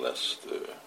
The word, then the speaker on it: Leicester: Hare Krishna, here we are in Leicester, which is written as Leicester, and bewildering pronunciation for those who are not used to England.